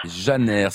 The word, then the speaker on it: Janer: Janer, J-A-N-E-R. – Janer, ça s'écrit Janer, ça se prononce Janer en fait, hein. – Voilà. – Parfait. – On prononce le R. – Exactement. Restez avec nous, hein, on dit le R.